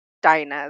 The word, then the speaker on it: Dina: Dina is how I pronounce it. Dina. Let's stick with Dina. – Okay. – I think Dina's prettier.